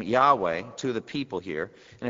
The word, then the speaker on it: Yahweh: Yahweh or Jehovah, there are different pronunciations, and you can pronounce it either way. But uh, I tend to go with Yahweh.